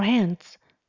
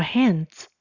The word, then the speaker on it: hands: 第二个动作，wash your hands frequently，wash your hands，洗你的双手，h a n d s，请留意 d s 的发音，念成子，像儿子的子。 Wash your hands，加了 frequently 也是一样，让对方知道要洗到什么样的程度，或是如何的洗。